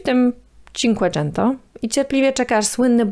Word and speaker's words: Cinquecento: Włosi powiedzieliby Fiata Cinquecento, bo tak właśnie wymawia się po włosku ten liczebnik, ale auto, którym wyruszył Altare, bardziej przypominało współczesnego Fiata 500 niż model znany w Polsce jako Cinquecento.